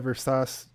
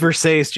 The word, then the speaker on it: Versace: No, because the next scene is them going to buy the Versace dress. – Yeah. – And it's revealed. – It's pronounced Versace. Sorry.